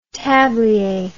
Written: tablier

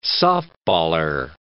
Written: softballer

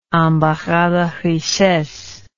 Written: embarras de richesses

